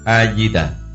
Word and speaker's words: A-di-đà: Chữ A trong danh hiệu Đức Phật A-di-đà phải đọc là A. Chữ không phải là Ơ.